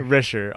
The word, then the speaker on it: Risher: Richer. R-I-S-H-E-R. – Okay. – Phonetically very close to Richer, which is very funny.